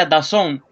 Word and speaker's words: DAZN: Eh, se, deletrea D-A-Z-N, pero se pronuncia Dazón.